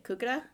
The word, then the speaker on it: Kukudh: Kukudh? – Yep.